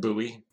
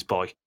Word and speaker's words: buoy: I'm not sure whether they use the word key, because they can't pronounce boy either, as in a boy floating in the water. – Oh, yeah. – Buoy. – They pronounce it buoy. – We also say buoy. – Yeah, you're wrong. Yeah. It's boy.